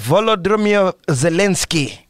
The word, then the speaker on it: Volodymyr Zelensky: President Volodymyr Zelensky.